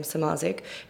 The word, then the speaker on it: Samardzic: And my handle is just Kate underscore, my last name, Samardzic, which is Kate underscore S A M A R D Z I C with a silent D.